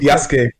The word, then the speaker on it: Yasuke: Is it Yasuke? Yasuke? What's the Yasuke. Yasuke. It's like how you pronounce Sasuke.